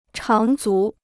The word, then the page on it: 长足: cháng zú